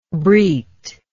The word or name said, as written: berith